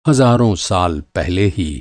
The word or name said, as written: हज़ारों